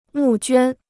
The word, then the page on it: 募捐: mù juān